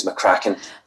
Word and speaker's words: McCracken: anyway, my second name is McCracken.